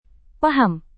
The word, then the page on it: paham: Indonesian